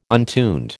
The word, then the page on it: untuned: American English